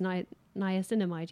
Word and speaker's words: niacinamide: The serums were a relatively new The Super Pure, which is niacinamide, which I can never pronounce, but it's basically just niacinamide. Yeah, I said it right.